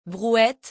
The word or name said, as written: brouette